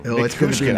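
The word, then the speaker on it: Nichushkin: Is it Lekkonen or Nikushkin? – Nikushkin. – I don't know why I can't say that. When I look at it, my brain freezes. Nikushkin. It's just like it's spelled.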